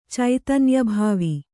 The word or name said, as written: ಚೈತನ್ಯಭಾವಿ